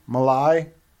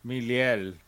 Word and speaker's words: Milei: Meliel. – Meliel. But that's not an L at the end, it's an I.